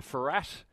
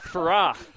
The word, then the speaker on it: Farrar: Is it Farrar? Farrat? How are we pronouncing it? – I started calling it Farrat, but quickly got pulled up. – Farrar. There you go. Got to really put that French twang on it, don't you? – You'll find Cap Farrar.